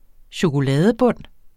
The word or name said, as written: chokoladebund